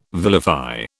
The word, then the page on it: vilify: British English